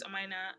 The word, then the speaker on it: Omaina: I'm still not completely correcting people when they mispronounce my name, because it's just like, I say, my name is Omaina, and they say, Omain.